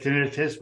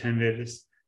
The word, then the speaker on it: tinnitus: Have a doctor friend pronounce it tinnitus. – So there you go. – I think that's right. – That's definitely right. – I've always said tinnitus.